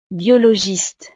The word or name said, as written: biologiste